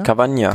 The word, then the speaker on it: Cavagna: Remy Cavagna, wie spricht man das aus? Cavagna? – Cavagna.